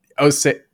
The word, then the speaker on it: OSEA: But there's this OC, is it Odyssey or OC? – Osea. – That's how you say it? – I think so. – Everything for me is ocean. So I thought certainly this is OC. It's OSEA, but they have a cleanser.